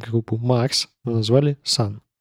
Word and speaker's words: Sunn O))): получается, это sun O? – Нет, читается именно как sun.